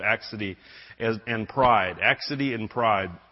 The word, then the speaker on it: accidie: Today we're going to cover the last two, which are the sins of accidie. How many remember that word? I didn't. Accidie, and that is how you pronounce it, which is often now called sloth.